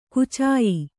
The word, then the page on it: ಕುಚಾಯಿ: kucāyi